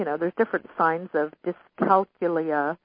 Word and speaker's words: dyscalculia: Why not whip out a word, and let me, correct me, if I'm not pronouncing it correctly, dyscalculia. Doesn't that sound wonderful? – Yeah. – D-Y-S-C-A-L-C-U-L-I-A.